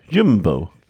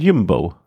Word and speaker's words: Jumbo: Der meinte so, man musste aussprechen U wie Ü. Jumbo.